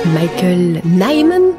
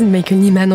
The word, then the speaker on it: Michael Nyman: donc Michael Nyman pour prononcer à l'anglaise, Michael Nyman en français, reprend euh, ce rythme pointé très présent chez Purcell.